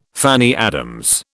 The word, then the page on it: Fanny Adams: British English